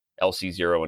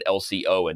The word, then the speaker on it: LC Zero: So, um, for, first will I'll share this video I did on uh, a game by LC Zero and Chiron, uh, saying it correctly now. In the video, I didn't know their names were actually LC Zero and Chiron. I said LCO and Chiron.